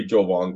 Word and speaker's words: Jovan: It's got to be Jovan Gwynn. – Jovan? – I am. – It's like Vince Vaughn, but Joe before it.